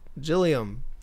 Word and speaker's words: Gilliam: Gilliam.